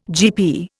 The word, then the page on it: GP: Indian